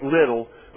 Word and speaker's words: Liddell: Eric Liddell, L-I-D-D-E-L-L, but pronounced Little, was born in 1902 in Tianjin, China.